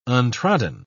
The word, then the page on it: untrodden: British English